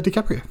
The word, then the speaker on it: DiCaprio: Leonardo Mr. Fister DiCaprio.